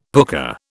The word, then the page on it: pooka: British English